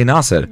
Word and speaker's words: Nasser: Just Nasser. It's not _sir because the Americans don't know how to say my name.